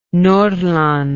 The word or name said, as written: Nordland